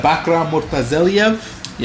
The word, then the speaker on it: Bakhram Murtazaliev: Um, the di- the IBF is ordering Jermell Charlo to fight uh, Bakram Murtazela. How do you pronounce that? You're better at that. – Uh, yeah, Bakhram Murtazaliev.